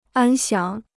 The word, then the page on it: 安详: ān xiáng